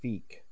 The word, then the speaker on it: Fikh: W-Y-V-R-E-N is pronounced Wyvern. T-A-R-R-A-N-T is pronounced Tarrant. G-A-N-D-O-R is pronounced Gandor. F-I-K-H is pronounced Feek. R-E-O-R-X is pronounced Reorks. X-A-N-D-A-R is pronounced Xandar. T-A-R-A-N is pronounced Taran. And G-L-O-R-I-D-A is pronounced Glorida.